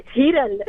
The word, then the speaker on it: हिरल: नहीं, हिरल, HIRAL.